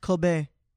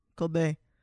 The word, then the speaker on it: Kobe: It's actually pronounced Kobe. Kobe, sorry.